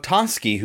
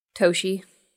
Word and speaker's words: Toschi: And so in honor of Toski, who I wouldn't think to pronounce his name Toski. How would you think to pronounce his name? – How's it spelled? – T-O-S-C-H-I. – Toshi. – Good. Yeah, or something similar to that. And when it's only written down and you're probably not hearing it very often, that's how I would think to pronounce it too.